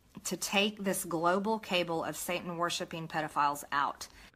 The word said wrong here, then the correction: cabal: And by the way, it's pronounced cabal. The root word being, or the the etymology of it, coming from the word Kabbalah, Jewish mystic practice, or whatever, some Jewish mysticism thing, or whatever.